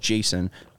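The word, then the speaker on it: Jasson: Yes, two S's, but still pronounced Jason.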